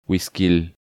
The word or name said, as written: Guisquil